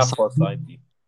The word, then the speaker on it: Forsythe: Wie, wie spricht man aus? – Ja, Forsythe. Forsythe. Okay, aus Runde 6, 208er Overall.